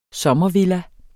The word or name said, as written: sommervilla